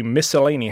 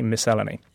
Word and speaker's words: miscellany: So I managed to say, I stress the second syllable, which is a tongue twister for anyone else. But panic overtook me. Anyway, a long way of saying, yes, I say miscellany.